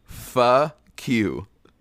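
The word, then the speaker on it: Phuh Queue: But the name is, it's spelled P-H-U-H space Q-U-E-U-E. – Okay. – So that means of origin? – That means, if you say it, it's fa-q.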